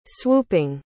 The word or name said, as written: swooping